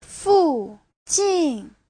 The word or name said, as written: fu4jin4